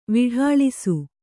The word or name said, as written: ವಿಢಾಳಿಸು